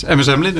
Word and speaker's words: MS Amlin: Zeg ik dat goed? – Ja, helemaal juist. Het is MS Amlin, een Japanse, Japanse verzekeraar.